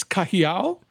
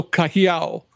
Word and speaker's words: Cajiao: It's Cajiao. – Cajiao. – So it's like Ka, like California, and then He, like He-Man, and then Ow, like somebody just pinched you. So Cajiao.